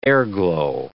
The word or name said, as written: airglow